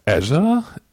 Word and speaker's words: Azure: Und unser Thema heute ist Windows Azure, spricht man das so aus? Also das ist vielleicht die allererste Frage. – Ich bin begeistert von dir, du hast es auf Anhieb richtig ausgesprochen. – Klingt ein bisschen hessisch, finde ich. – Also, ja, wirklich, wir als Deutsche, wir könnten uns das eigentlich am besten merken, wenn wir so ans südhessische Ärger denken, ne?